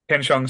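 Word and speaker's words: Ken Jeong: It was Ken Chuang. I probably said it wrong. Came out The Hangover and stuff like that. – Oh, yeah. – Ken Chiang, sorry.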